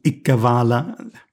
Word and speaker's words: Ikkavala: Well, the ones we see here are Iqbal becoming Ikkavala. But now I'm giving it the classical Sanskrit pronunciation, mm-hmm, including the A at the end.